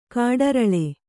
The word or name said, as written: ಕಾಡರಳೆ